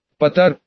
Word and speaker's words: Потар: Потар, ударение ставилось на «а», задумчиво пожевал губы, после чего бросил взгляд на двери бара.